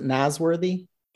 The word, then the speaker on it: Nasworthy: Do I pronounce it Nasworthy? – Yes, sir, that's correct.